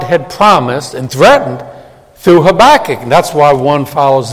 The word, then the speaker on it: Habakkuk: Now, there are several ways to pronounce that name. Habakkuk. Some pronounce it, what? Habakkuk. So where you put the accent.